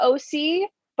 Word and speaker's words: OSEA: Yes, the OC body oil. This is OC. It's spelled OSEA and it's the Undaria algae body oil.